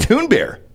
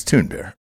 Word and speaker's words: Thunberg: Toon Bear. Please pronounce that correctly. It's Toon Bear.